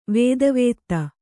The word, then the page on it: ವೇದವೇತ್ತ: kannada